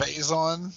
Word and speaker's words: Faison: And then in other TV news, Donald Faison? I don't know how to pronounce his name. – It's Faison.